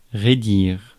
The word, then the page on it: stretch: /stretʃ/